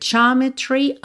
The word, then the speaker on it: geometry: The Sacred Geometry of Chance. Então, geometry, tá? Pensa que esse ja é uma sílaba só, não é geo, como a gente pensa em português, tá?